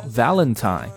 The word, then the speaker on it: Valentine: 好， Valentine V-A-L-E-N-T-I-N-E Valentine。